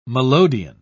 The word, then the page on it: melodeon: British English